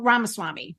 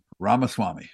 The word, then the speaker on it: Ramaswamy: Vivek Ramaswamy. – Ramaswamy.